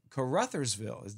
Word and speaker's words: Caruthersville: We're coming to you from the Grizzly Jig Spring Tackle Show in Caruthersville. Did I pronounce it right? – You did.